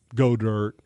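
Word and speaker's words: Goedert: He's not anywhere near some of these other guys like Disley or Godert. – Though I love the Notre Dame. – It rhymes with yogurt.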